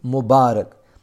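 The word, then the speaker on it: مبارك: Answer: some people pronounce this with a kasra on the letter ra, but the correct way is to pronounce it with a fatha, i.e. Mubarak.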